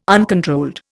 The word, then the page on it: uncontrolled: Indian